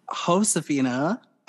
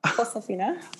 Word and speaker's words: Josefina: So Josephina. – Josephina. – Josephina. My grandma would not let me say her name any other way.